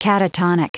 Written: catatonic

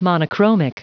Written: monochromic

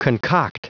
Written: concoct

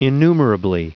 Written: innumerably

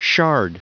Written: shard

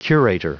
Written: curator

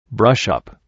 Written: brush up

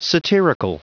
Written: satirical

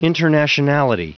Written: internationality

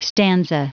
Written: stanza

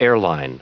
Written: airline

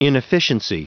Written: inefficiency

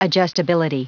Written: adjustability

